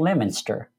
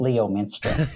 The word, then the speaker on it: Leominster: It's a town of about 20,000 people, and it's called Leominster, and it's spelled Leominster. So my GPS always calls it Leominster.